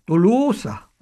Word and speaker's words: Tolosa: Les textes grecs sont intéressants pour la prononciation, parce que les textes grecs sont écrits avec un omicron pour to et un oméga pour l'o. – Un o qui traîne. – Ce qui prouve que un o qui traîne et un o qui est fermé. Et donc la prononciation devait être Tolosa.